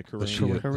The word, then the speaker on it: Karriem: K-A-R-R-I-E-M. – Yeah, Kareem.